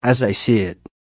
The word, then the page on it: as i see it: American English